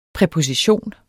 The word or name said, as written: præposition